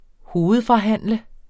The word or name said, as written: hovedforhandle